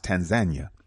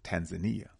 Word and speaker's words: Tanzania: And then in November, November 18th through the 29th, Tanzania, formerly, but it's pronounced Tanzania, but the West pronounces it Tanzania. So I don't want to confuse nobody. Make sure you got it right, Tanzania.